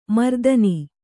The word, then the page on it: ಮರುದಿನ: Kannada